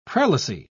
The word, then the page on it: prelacy: British English